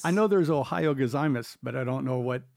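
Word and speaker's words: Ohio gozaimasu (おはようございます): Ohio gozaimasu.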